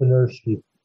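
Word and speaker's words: Benirschke: I think it's Benersky. Rolf Benersky, yes.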